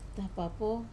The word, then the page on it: tapapo: Wamesa